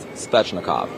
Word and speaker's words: Svechnikov: It's Svechnikov. Svechnikov. Have to pronounce that 10 times over.